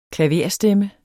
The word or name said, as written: klaverstemme